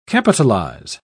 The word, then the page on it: capitalise: British English